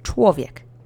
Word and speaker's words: człowiek: Now you're ready to say człowiek. Let's say człowiek together three times slowly. The accent is on the first syllable, on the tw part.